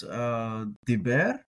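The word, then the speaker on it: דִּבֵּר: But when we look at Genesis 12:4, we notice that the Dalet and the Beit both have Dagashim. So this is Diber.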